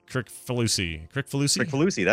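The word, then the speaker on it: Kricfalusi: Crickfalusi. That's how you say it, actually.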